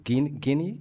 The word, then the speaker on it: Guinea: We are still dealing with this thing, and it has spread to Guinea. Is that how you say it? – Yeah.